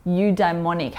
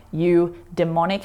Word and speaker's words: eudaimonic: And that good nutritious meal in psychology world is called eudaimonic happiness. And I'm careful in how I say that, because I think that it's actually pronounced more like you demonic happiness, but I just wanted to clarify that that's not what I'm talking about without it being on the screen.